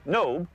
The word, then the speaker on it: Nob: known as Nob, though more properly pronounced in Hebrew, Nob, was once located.